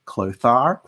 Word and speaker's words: Clothar: So the name Clothar, which you weren't sure how it's pronounced. – Yeah, because it's spelled C-L-O-T-H-A-R. – I haven't seen Clothar. I don't know. – I read it as Clothar. – In French, it is Clotaire. – I also read it as Clotaire as well. Yeah, it's C-L-O-T-A-I-R-E.